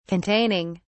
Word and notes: containing: British English

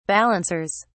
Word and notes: balancers: British English

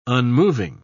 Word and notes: unmoving: British English

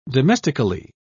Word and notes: domestically: British English

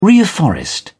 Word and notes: reafforest: British English

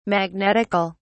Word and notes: magnetical: British English

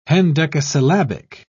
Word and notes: hendecasyllabic: British English

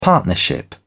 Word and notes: partnership: British English

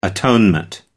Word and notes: atonement: American English

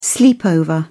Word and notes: sleepover: British English

